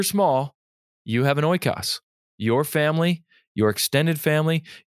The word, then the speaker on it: oikos: It's the word oikos. You can also pronounce it oikos if you wish. It's O-I-K-O-S, oikos, O-I-K-O-S.